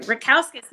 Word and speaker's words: Rackauskas: Rackauskas, you, you nailed it.